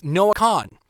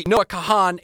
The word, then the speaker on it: Kahan: On top of it is Noah Kahan, because apparently Noah Kahan is wrong, and it's Noah Kahan. Also, it's poor. P-O-O-R.